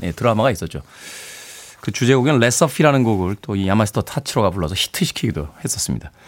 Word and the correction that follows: 야마시타 타츠로: 멜리사 맨체스터와 야마스타 야마스 야야 타치로 네 야마시타 타츠로 일본 분들 발음이 참 힘드네요.